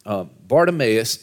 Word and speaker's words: Bartimaeus: Bartimaeus is the Hebrew pronunciation of a Greek name, okay? Bar means son of, and then Timaeus is the name. So he's the son of Timaeus, Bartimaeus.